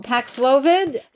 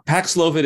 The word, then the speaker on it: Paxlovid: Paxlovid, the Pfizer drug.